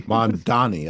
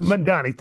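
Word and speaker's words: Mamdani: You mentioned the Mondavi Is it Mandavi? – Mandani, I think it is. – Mandani, thank you.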